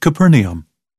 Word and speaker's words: Capernaum: Or Capernaum or Capernaum. I have been asked to pronounce it Capernaum.